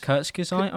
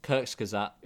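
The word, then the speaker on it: Kurzgesagt: Is that how you say it? How do you say it? – Kurtz Gesite, I'm pretty sure. – Kurtz Gesite.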